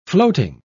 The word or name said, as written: floating